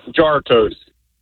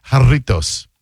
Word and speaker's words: Jarritos: Pronounced Jar Toast. – What happened? – Pronounced Jar Toast. – Well, I guess if you're a dumbass, it is. But no, it's Hispanic, so it's pronounced Jarritos.